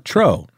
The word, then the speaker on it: Truax: Another new crusher, Canem Truex, or is that pronounced Tro? – Yeah, Tro. – Tro? – Maybe. Is it E-A-U-X? – T-R-U-A-X. – Now, I don't know about that. That's not a French O sound.